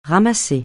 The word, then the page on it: ramasser: francais